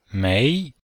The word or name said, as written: mei2